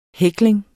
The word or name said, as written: hækling